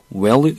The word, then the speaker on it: will: will you 뭐뭐 이렇게 하지 않고요. well 이렇게 발음하는 경우가 많습니다.